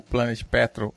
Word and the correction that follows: Planet Patrol: Eu falei errado. O nome correto é Planet Patrol.